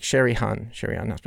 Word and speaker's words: Sherry Osman: And Sharian. Oh, man. I always say this wrong. Sherry Osman. But it's like Sherry Osman.